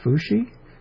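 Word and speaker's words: Fu Shi (伏羲): It's pronounced Fu Shi, rising, and then even tone.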